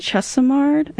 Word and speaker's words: Chesimard: Asada Shakur, actually, she was originally Joanne Chesimard, I think. – Yes. – I'm pronouncing it right.